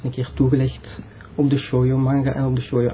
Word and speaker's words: Shoujo: Shoujo is dus de manga en de anime voor meisjes of voor vrouwen.